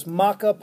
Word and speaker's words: maca: There's maca powder.